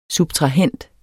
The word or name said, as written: subtrahend